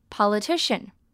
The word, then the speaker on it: politician: 它这边的发音呢要注意它的重音在 ti 的地方， po 的 o 是发 r 的音， li 的 i 只有发短音 politician。